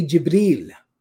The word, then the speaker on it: Jibril: There are three ways that it is pronounced in the Arabic language, depending on the tribal dialect. According to the people, the Arabs of Hijaz, they would say Jibril, Jibril, and that's how I read it in the verse.